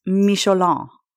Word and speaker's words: Michelin: The Michelin Man. Pronounced Michelin.